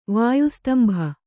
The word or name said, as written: ವಾಯುಸ್ತಂಭ